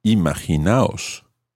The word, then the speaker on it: imaginaos: So the example in today's conversation was imaginaos.